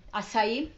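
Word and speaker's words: açaí: Acai? – Yes.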